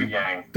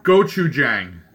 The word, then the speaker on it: Gochujang: As we reach towards the bottom of the bracket, we've got duck sauce going up against Goku Jang. – Listen, Gochujang. – Duck sauce. – Gochujang. Yeah, like what I. That's what I said. – Pronounce that for us, would you? – Gochujang.